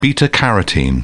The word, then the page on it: beta-carotene: British English